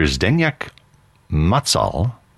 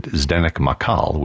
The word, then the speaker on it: Zdeněk Macal: Some Dvorak right now, very nationalistic Dvorak, as this is conductor Zdeněk Macal, although in the United States he pronounced it Zdeněk Makal, with the Milwaukee Symphony and the polka from Dvorak's Czech Suite.